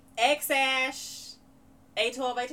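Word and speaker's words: X Æ A-12: Um, I mean, it's just X, the letter X, and then the AE is pronounced like ash, and then A12, A12.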